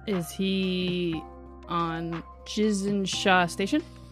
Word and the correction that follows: Gizen-Sha: I need to change it from a J to a G. – It is a G.